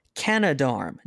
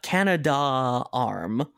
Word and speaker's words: Canadarm: a tote bag that says, it's pronounced Canadarm, not Canada-arm, with a lot of A's.